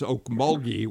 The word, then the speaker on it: Okmulgee: It's Okmulgee with a hard G, not a soft G, as I said.